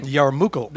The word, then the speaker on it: yarmulke: Yarmulke. – The yarmulke. And Yarmulke. – You say it again. – Yarmulke. – That's exactly how it should be said.